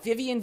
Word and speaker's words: Vivian: Well, they can look me up, and that would be at my name, which is like a mouthful, at Vivian Bercovici. – Um, Spell it. – B- oh my God, Vivian V I V I A N.